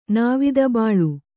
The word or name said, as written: ನಾವಿದಬಾಳು